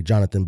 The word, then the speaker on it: Yonatan: Y-O-N-A-T-A-N. He says it's pronounced like Jonathan, but with a Y. So Jonathan.